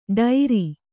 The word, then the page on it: ಡೇರಾ: ḍērā